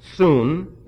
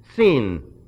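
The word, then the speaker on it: sin (σύν): The sin there, by the way, commonly pronounced soon, it's sin.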